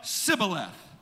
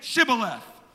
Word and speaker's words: shibboleth: They could not say shibboleth. And so, try as they might, it always came out sibboleth. They began to go through the entire clan and ask them, "Say shibboleth."